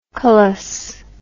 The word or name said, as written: colous